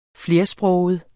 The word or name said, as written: flersproget